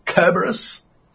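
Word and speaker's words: Cerberus: Finished playing Dirge of Kerberos today with a hard C, Kerberos. How you like that? – Okay.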